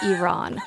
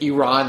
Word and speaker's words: Iran: Is please pronounce it Iran and not Iran. The joke is, and most don't get the reference, Iran is an 80s song by Flock of Seagulls.